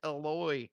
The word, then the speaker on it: Eloy: There's no R in his name, dude. It's Eloy.